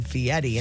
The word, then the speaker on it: Fieri: But he also wants you to pronounce his name Fieri. And his name is Ferry.